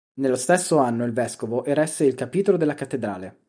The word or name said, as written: cattedrale